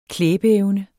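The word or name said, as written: klæbeevne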